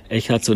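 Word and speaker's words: Echaz: Du hast 2015 deine Spielerkarriere beendet und dann warst du erst bei der JSG, wie spricht man das aus, Echards Erms? – Ja, das war eigentlich parallel schon. Echards und Erms, das sind die zwei Flüsse, die durch Pfullingen und Neuhausen fließen.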